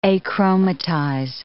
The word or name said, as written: achromatize